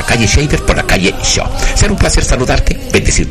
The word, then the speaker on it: Shaw: Bueno, aquí estamos, 605 Shaw, SHAW, 605, se pronuncia Shaw, y en hispánicamente hablando en español, Shaw, esa calle de las Cortes, de la Clínica Strawberry.